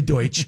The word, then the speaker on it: Deutsch: Ada, it was called Douche Oper in Berlin. Douche. – I don't think Douche that's the pronunciation. What do you think, Jeff? – Douche. – No, it's actually Deutsch.